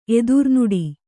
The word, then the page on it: ಎದುರ್ನೋಡು: kannada